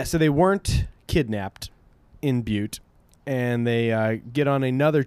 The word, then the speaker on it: Butte: At Butte, they were wary. – I pronounce that butt. – It's Butte, and there's a couple times where it's funnier to say butt.